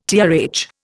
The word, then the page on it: TRH: Indian